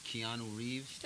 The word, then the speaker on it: Keanu Reeves: Keanu Reeves.